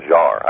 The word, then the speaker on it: Jarre: I think the actual pronunciation is Jean-Michel Jarre. I don't think he said Jarre. I said Jarre for years, but then I heard some French person say it, yes.